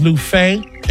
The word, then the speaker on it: Luffeigh: Last name is Lufe. L-U-F-F-E-I-G-H. And one F is silent.